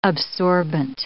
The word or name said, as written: absorbent